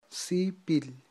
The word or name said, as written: sipil